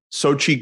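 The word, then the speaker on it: Xochitl: I think the way you pronounce it is Sochi. Yeah. Sochi Gomez. – Okay. – Yeah, it's like a weird spelling, but it's just Sochi.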